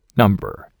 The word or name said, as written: number